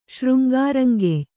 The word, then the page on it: ಶೃಂಗಾರಂಗೆಯ್: kannada